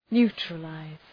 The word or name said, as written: neutralize